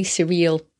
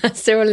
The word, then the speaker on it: Cyril: And I will soon be announcing how to market a book in French, thanks to Cyril Godefroy, and it's probably surreal Sorry, Cyril. Cyril is very English way to pronounce that name.